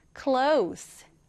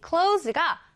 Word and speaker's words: close: 자, 여러분, 가까운 혹은 가까이라는 의미로 쓰일 때는 close, S 발음이 나요. 얘가 S 발음이 나요. 그런데 이 close가 닫다라는 의미로 사용이 될 때에는 close가 아니라 close, Z 발음이 나게 됩니다.